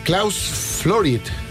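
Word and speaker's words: Klaus Flouride: 30 de mayo, 77 años de 1949, Klaus Florid.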